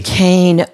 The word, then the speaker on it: Duquesne: You did yell at me about I couldn't pronounce Duseki University. – Duquesne. Oh my gosh.